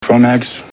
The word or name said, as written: Kromaggs